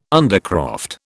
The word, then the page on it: undercroft: British English